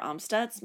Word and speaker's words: Amstutz: You can find me on all social media platforms at Sandra Amstutz. My last name is spelled A-M-S-T-U-T-Z.